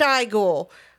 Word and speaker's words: Igor: So another really great gag, right, is we know that he calls himself Frankenstein, and then his assistant, who's normally called Igor, he goes, it's pronounced Igor.